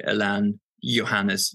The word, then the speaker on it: Alain Johannes: Alan Johannes? – That was perfect too. Yeah, the Americans, they usually go for Alan and Johannes. Alan Johannes, but he's born in Chile and he grew up in Europe and stuff.